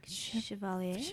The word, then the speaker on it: Chevalier: They did do the title song, which, by the way, they got Maurice, how do you say his last name? – Chevalier.